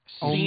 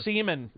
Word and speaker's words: Seaman: No, and for people only on the audio version, that's spelled S-E-A-M-A-N. Yeah.